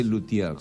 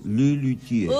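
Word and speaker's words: Les Luthiers: No se dice Luthier, se dice Le Luthier.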